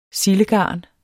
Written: sildegarn

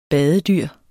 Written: badedyr

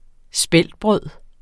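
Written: Speltbrødet

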